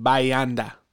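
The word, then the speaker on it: Bahia Honda: Couple interesting places, Bahi Honda State Park. And we met a young lady who was from New York, then Miami, then the Keys. She said, Bayanda is how you say it. So Bayanda.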